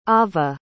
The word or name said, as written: avah